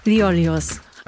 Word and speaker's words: Liolios: I'm sitting in Frankfurt today with Nektarios Liolios.